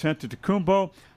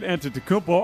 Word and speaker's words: Antetokounmpo: The real big question mark is Giannis Antetokounmpo.